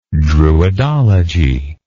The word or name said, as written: druidology